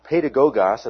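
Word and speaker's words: paidagogos: Paul used this word that's pronounced pedagogos. It sounds something like paid to go get gas, okay?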